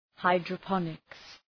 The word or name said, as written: hydroponics